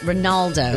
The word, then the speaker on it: Ronaldo: Cristiano Ronaldo. Did I say it right? – I don't think there's quite as many D's in there. – Okay. Ronaldo.